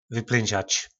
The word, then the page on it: vyplynzac: po našimu